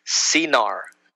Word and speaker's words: Senaar: The land of Shinar, the Septuagint and the Vulgate, which is the Latin Bible, it is pronounced Sinar, S-E-N-A-A-R. In the inscriptions, Shumir, or S-H-U-M-I-R, probably identical with Babylonia or southern Mesopotamia, extending almost to the Persian Gulf.